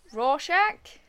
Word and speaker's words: Rorschach: Rorschach? – Mm.